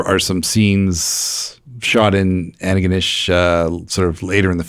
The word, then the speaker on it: Antigonish: Antigonish.